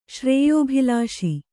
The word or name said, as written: ಶ್ರೇಯೋಭಿಲಾಷಿ